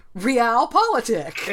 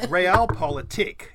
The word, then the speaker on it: Realpolitik: Realpolitik. – It's sort of like realpolitik, but a realpolitik. – It's the emphasis is on the tik.